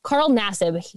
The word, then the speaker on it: Carl Nassib: Carl Nassib, that is his pronunciation.